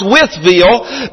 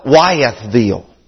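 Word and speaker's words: Wytheville: Us being from Withville, properly pronounced Withville, but most people around call it Wyethville.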